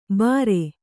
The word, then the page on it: ಬರೆ: bare